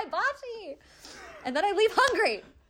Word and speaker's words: hibachi: It's hibachi. I did Japanese karate. I know just enough Japanese to know it's pronounced hibachi.